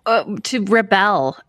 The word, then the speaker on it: Rebelle: How is things on the road to Rebel? – Rebelle. – I can't say it properly.